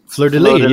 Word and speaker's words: fleur-de-lis: How's it supposed to be pronounced? French. You know, it's French. It's at the very least. – Fleur-de-lis. – Yeah. Yeah. Right. Exactly. You don't put the S on the end.